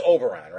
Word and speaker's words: Oberon: And it is Oberon, right? I'm pronouncing that correctly? Yeah. Okay.